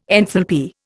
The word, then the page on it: enthalpy: Indian